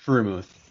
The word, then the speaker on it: Freiermuth: Pat Freiermuth.